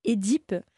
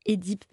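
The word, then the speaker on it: Œdipe: tout comme « édipe », qui, voilà, les profs de lettres qu'on a à la fac, ils nous disent « c'est bien édipe » parce que ça vient du grec, etc.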